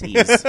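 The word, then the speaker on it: Ys: That's the studio behind the Ease series, not to be confused with Wise. It's Ease. That's how you say it.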